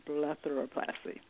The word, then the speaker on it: blepharoplasty: And that brings me to the next question, which is, what should patients be looking for when choosing a cosmetic surgeon for blepharoplasty? Did I say it correctly? – You did well.